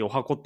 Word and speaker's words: 十八番: うん、あ、十八え、お箱ってやつですかあ、そう。うん。あのね、なんか、歌舞伎の中では 18 番って書いて、お箱って読むことほとんどないんですけど、うん。